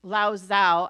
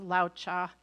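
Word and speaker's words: Lao Tzu: I think it's, I always want to say Lao Zhao. I think it's pronounced Lao Cha.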